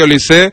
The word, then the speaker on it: Olise: es Olise. Según Pablo Polo, Olis. El que controla el francés dice que se pronuncia Olis. Aquí en España Olis. Le llamamos Olise porque nos sale del forro.